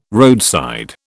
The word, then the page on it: roadside: British English